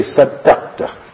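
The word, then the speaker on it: Saddaqta (صدقت): And that also must pronounce it correctly, one good person. He should say with shock, he should give Ramzan all got more shock in it. He should say Saddaqta.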